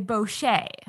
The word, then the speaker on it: bochet: And then there is a bochet which I pronounce like that because I feel like it's a French word, which is mead made with caramelized honey.